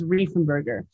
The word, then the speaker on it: Reifenberger: Is it Reifenberger? – It it's it looks like it's spelled that way. It's it's Reifenberger. – Reifenberger.